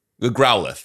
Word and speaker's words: Growlithe: Dude, is that how we Growlithe. were told? – Growlithe. That's it. Growlithe. I know we got schooled on how to say it.